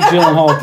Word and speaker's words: Gyllenhaal: Jake Gyllenhaal Potato Pie.